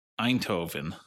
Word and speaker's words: Eindhoven: It's about a brand identity made for the city of Eindhoven.